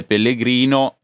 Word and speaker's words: Pellegrino: Ti chiedo invece, ecco, sulle scelte, sai che l'uomo della settimana ovviamente è Pellegrino, che allora abbiamo appurato da pronuncia è sostanzialmente con una L,